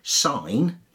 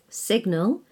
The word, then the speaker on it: sign: And finally, sign, S-I-G-N, you don't pronounce the G. – Signal, you pronounce the G.